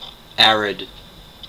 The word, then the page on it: arid: American English